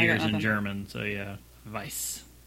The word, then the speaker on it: Weiss: That's probably what that pronunciation they were trying to get me to say, which was Weiss beer. – Oh, yeah. That weird B-looking thing is pronounced like two S's.